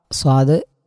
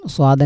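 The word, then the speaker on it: സ്വാദ് (صاد): സ്വാദ് പറയുമ്പോൾ സീനാവരുത്, രണ്ടും അക്ഷരങ്ങൾ സെയിമായിട്ട് ഉച്ചാരണം വരുന്നത് ശ്രദ്ധിക്കുക. രണ്ടും സ്വാദ് തന്നെയാണ്, സീനാവരുത്.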